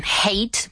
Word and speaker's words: hate: Muy bien, hate, pronunciado con el sonido H y no J.